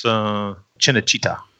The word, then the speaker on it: Cinecittà: I always mispronounce it. Cinecittà? Does anybody know if that's right, the Italian? – I believe it's Cinecittà.